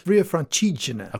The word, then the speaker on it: Via Francigena: Via Francigena.